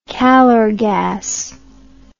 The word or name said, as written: calorgas